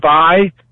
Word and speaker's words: Ai: You're talking about Jericho, you know, very famous places we've heard of, and maybe less famous ones like Ai or I. We pronounce it I.